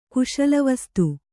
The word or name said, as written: ಕುಶಲವಸ್ತು